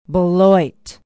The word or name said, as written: beloit